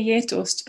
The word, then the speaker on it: Geitost: How do you pronounce that? – I would say Geitost, but I'm sure that's a Swedification.